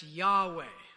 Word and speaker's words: Yahweh: The divine name there is YHWH. – Igama eliphakeme yileli ke umfundisi alishoyo. – Which we would say as Yahweh.